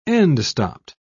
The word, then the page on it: end-stopped: British English